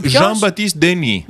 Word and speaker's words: Jean-Baptiste Denis: Jean-Baptiste Denis. Σε παρακαλώ να το λες με γαλλική προφορά, γιατί δεν το ανέχομαι.